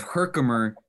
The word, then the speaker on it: Herkimer: Jason Crippen from Revamp Fitness out of Herkimer, New York.